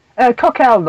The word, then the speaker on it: Cockle: Coquel, normally. It's a French name.